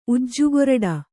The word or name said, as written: ಉಜ್ಜುಗೊರಡು